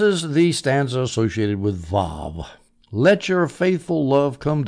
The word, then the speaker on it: Vav: Now, I saw a very interesting comment on YouTube by a scholar who was saying it should be pronounced Vav because most Jews pronounce it that way. There's some Jews who have Arabic as their native language who pronounce it Waw. But he says, no, it should be Vav, so I'm going to take his word for it.